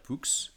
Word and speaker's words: Poux: Alors, euh, du coup, je m'appelle je m'appelle Pierre Poux. Le X se prononce.